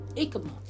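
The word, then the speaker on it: jícama: It's J-I-C-A-M-A. I said Jicama. But she says it was, I think the J is silent. Ikama. That's it. Ikama.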